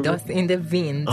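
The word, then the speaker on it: Dust in the Wind: به درست تلفظ کنید داست این ویند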